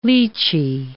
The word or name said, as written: litchi